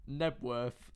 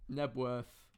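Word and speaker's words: Knebworth: Robbie Williams, Nebworth, Let Me Entertain You. – How do you spell it? – K-N-E-B. – K-N-E-B. – Nebworth, Let Me Entertain You.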